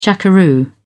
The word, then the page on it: jackaroo: British English